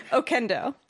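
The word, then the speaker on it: Oquendo: Okendo. – I would have never gotten anywhere close because, to that. – And then I would tell people, the Q, it's pronounced like K, like Quesadilla, because it's a Hispanic last name.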